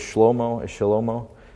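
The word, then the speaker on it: Shlomo (שלמה): She gave birth to Solomon, whose name means peace, Solomon's real name, if you want to pronounce it in the Hebrew way, is Shlomo, which doesn't sound as kingly, you know, does it?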